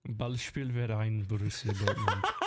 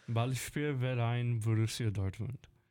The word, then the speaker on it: Ballspielverein Borussia Dortmund: Ballspielverein Borussia Dortmund. – Say it again. – Ballspielverein Borussia Dortmund.